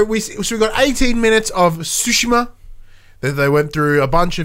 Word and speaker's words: Tsushima: As I said at the start of the show, Tsushima. Not Tsushima.